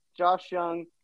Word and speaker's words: Josh Young: And then rounding out the top, the six players at number 34, Josh Young. Really not a whole lot that soft J. – Soft J, not Jung.